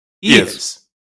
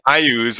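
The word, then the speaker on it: Iuz: How did he pronounce it? – Yes. – Ayaz. There you go. – I know people say Ayuz, and that sounds grosser. So I applaud you if you want to call it Ayuz.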